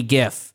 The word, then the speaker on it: GIF: People are just doing that with cultural artifacts, like a funny GIF. And by the way, it's a hard fucking G. – It's a hard G.